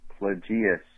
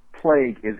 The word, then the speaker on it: Plagueis: However, incredibly annoyed by the pronunciation Dark Plagueis. Wow. How could it possibly be Plagueis? First, plague is in the name.